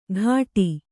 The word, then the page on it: ಘಾಟಿ: Kannada